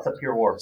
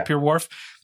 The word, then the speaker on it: Sapir-Whorf: This, I've never known how to say it, Sapir-Whorf? – Oh, Sapir-Whorf, yeah. – Sapir-Whorf.